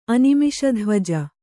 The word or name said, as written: ಅನಿಮಿಷಧ್ವಜ